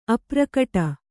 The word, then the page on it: ಅಪ್ರಕಟ: aprakaṭa